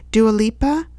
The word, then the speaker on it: Dua Lipa: Dua Lipa, I think is how this musician's name is pronounced, D-U-A, and the last name is L-I-P-A.